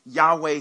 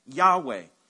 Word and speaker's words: Yahweh: L-O-R-D. It's referring to God's name. And so in English, it's Lord. But in the Hebrew, it's pronounced something like, they think, Yahweh.